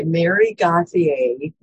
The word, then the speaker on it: Gauthier: I'm going to share a song by Mary Gauthier is how you say her name.